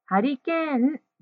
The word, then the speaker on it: ハリケーン: 4 番の問題のハリケーンですが、ちょっと発音が英語のようになっていたので、ハリケーンとしっかり発音しましょう。